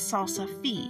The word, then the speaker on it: salsify: Now, I usually call it Salsify, but the actual name is Salsify. So I don't want to tell you the wrong thing. I was corrected on one episode because I was using a common pronunciation that's here, but that pronunciation is incorrect. It's actually Salsify, not Salsify, no matter what it looks like.